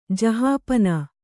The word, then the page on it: ಜಹಾಪನಾ: Kannada